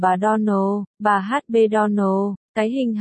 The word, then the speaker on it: Donald: Này, cô Shirley, cách phát âm đúng của tên của chúng tôi là Donald. Nhấn giọng vào âm tiết cuối cùng.